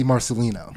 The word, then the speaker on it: Marcellino: And today our guest is none other than the Josie Marcellino.